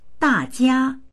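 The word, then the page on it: da4jia1: Mandarin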